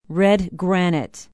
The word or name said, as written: Redgranite